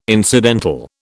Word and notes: incidental: British English

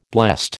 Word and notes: blessed: American English

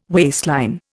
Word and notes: waistline: Indian

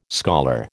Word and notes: scholar: American English

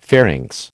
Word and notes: pharynx: American English

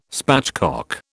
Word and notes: spatchcock: British English

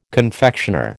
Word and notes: confectioner: American English

